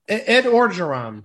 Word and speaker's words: Ed Orgeron: Ed Orgeron.